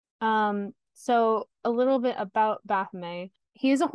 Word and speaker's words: Baphomet: But speaking of gods, there is a statue of Baphomet, or Baphomet, however you want to pronounce it. I'm going to say Baphomet because it's originally a French word.